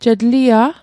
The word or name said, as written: je tlia'